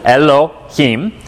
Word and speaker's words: Elohim: And this is how you pronounce it, Elohim.